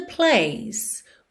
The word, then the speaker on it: place: Place. Termina nesse som do C. Place, place. Não vai para a letra E, tá? A letra E aqui é muda.